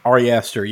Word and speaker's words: Ari Aster: Ari Aster, yeah, the guy who did Hereditary and yeah.